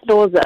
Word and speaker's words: Loser: Uh, no, you said it correctly. It's Loser.